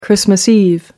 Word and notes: christmas-eve: American English

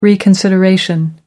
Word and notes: reconsideration: American English

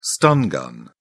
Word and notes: stun gun: British English

